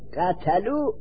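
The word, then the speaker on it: قتلوا: یکی الفی که بعد از واو جمع می نویسند وربو بعد از واو الف می نویسند قتلو بعد از واو الف می نویسند این الف تلفظ نمیشه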